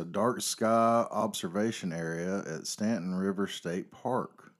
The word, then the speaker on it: Staunton: I think it's pronounced Stanton, but it's spelled S-T-A-U-N-T-O-N.